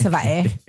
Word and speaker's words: Hawaii: And then you're going to start pronouncing it Hawaii. – They did. We pronounced it wrong. – Out of respect, you'll be like, I can't even hit y'all with the Hawaii. It's Hawaii. All right.